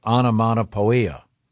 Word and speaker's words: onomatopoeia: But anyway, the description was, it was described as starting with explosive onomatopoeia. – Onomatopoeia. It's spelled poia, but it's pia.